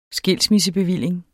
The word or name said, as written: skilsmissebevilling